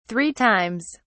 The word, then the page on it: three times: British English